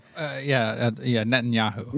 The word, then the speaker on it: Netanyahu: Am I saying it right? – Netanyahu. – Netanyahu.